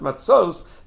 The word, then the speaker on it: matzos: Even though it's pronounced matzos, the ksiv is matzas.